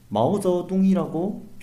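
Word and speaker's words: 毛泽东: 예, 사실 이게 표준어 표기법에 따르면 모택동이라고 하면 안 되죠. 근데 마오쩌둥이라고 표기를 네, 저도 하고, 그렇게 예, 해야 외로운 되는데, 표기 발음 나는.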